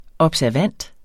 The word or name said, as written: observant